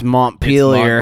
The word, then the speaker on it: Montpelier: It's Montpellier. – It's Montpellier, yeah. – Montpellier. Sorry, I went for the French pronunciation.